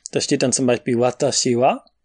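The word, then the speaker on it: Watashi wa (私は): Und äh, über Ta, Si und Wa ist ein Strich. Das deutet an, dass das Wa nach dem Wa es eine Erhöhung gibt, also das Ta ist höher als das Wa. Wata, wata. Da geht die Stimme hoch. Und Tashi wa ist dieselbe Tonhöhe.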